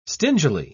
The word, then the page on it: stingily: British English